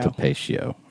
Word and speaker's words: Tapatio: Topatio. – That's how you pronounce that.